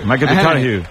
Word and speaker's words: McConaughey: ¿Mateo McConaughey? – Sí. – Hay un futbolero, le gusta. Hay que ir corrigiendo la pronunciación. – McConaughey.